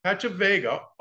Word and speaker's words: Pachavega: and founder of Pachavega. Did I pronounce that right? Yeah. Oh, perfect.